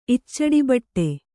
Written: ಇಚ್ಚಡಿಬಟ್ಟೆ

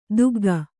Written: ತೆಗ್ಗು

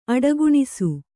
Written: ಅಡಗಾಣಿಸು

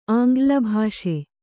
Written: ಆಂಗ್ಲಭಾಷೆ

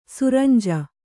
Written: ಸುರಂಜಿ

